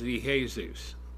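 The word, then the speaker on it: Iesous: And I tell people, you know, quite clearly, in the Greek, the name of the Lord is Iesus. That's how you pronounce it. I-E-S-O-U-S, I believe.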